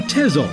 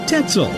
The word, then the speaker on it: Tezel: Today, we say Tezel. In German, the Z is pronounced with a T, as you'd say Mozart. Next time you give directions, consider saying Tetzel.